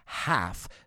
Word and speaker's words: half: Cuidado con esta palabra, half. No pronunciamos la L, no half, sino half.